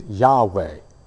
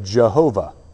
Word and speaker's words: Yahweh: It's Yahweh. Or if you were, you know, early Latin scholars, you would have said Jehovah because you didn't understand where the vowels went. So if you've heard Jehovah, that's simply the divine name, Yahweh. It's just a different pronunciation just based on vowels.